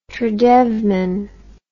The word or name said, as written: tradevman